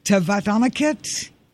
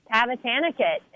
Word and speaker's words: Tavatanakit: And I hear that tied for six right now is Patty Tavatanakit. Did I say her name right? – Close. Tavatanakit.